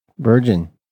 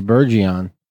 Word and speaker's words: Virgin: There you go, it's Virgin. Virgin, that's how you say it. It's official. Because I've had people come to me and say Bergeron.